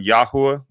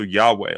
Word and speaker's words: Yahweh: A lot of people, there's a couple of different camps on the pronunciation of YHWH. There's a Yahuwah. That's what I use, is Yahuwah. And then there's also Yahweh. A lot of people use Yahweh as well.